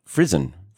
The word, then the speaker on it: frisson: Frisson. Frisson. But frisson. Every pronunciation I found was frisson. Frisson really carries that frisson. French origination. Frisson.